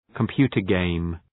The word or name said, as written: computer game